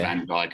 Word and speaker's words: Van Dijk: Van Dijk who's had a terrible injury.